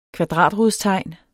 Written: kvadratrodstegn

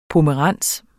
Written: pomerans

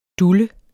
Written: DULLEN

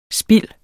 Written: Spild

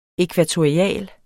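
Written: ækvatorialt

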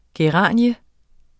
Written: geranie

